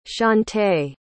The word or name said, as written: shawnte